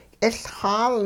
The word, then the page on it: k'iLxaal: hupa